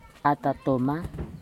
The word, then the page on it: atatoma: futuna_aniwa